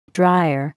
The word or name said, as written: dryer